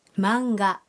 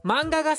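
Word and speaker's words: مانغا (マンガ): هذه القصص المرسومة تنطق هكذا مانغا مانغا سكي مانجا الآن يمكنكم الحديث عن الأشياء التي تحبونها.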